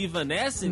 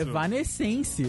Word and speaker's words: Evanescence: Exato, você pronunciou da, da maneira correta, porque esse negócio de Ivanescense... – Não, porra é pra, nenhuma. Não é pro Brasil isso. Evanescence. Evanescence. – Evanescence. É isso aí.